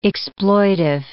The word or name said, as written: exploitive